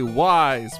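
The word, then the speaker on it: Ys: Jesse and David both say Wise, which is correct.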